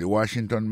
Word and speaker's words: Washington: Si n'a mettez accent sur accent encore, on voit que mot Washington là, accent tombé sur premier syllabe là. Pour mot monument, il tombé sur premier syllabe là. The Washington Monument. Monument, c'est comme ça on dit monument en anglais.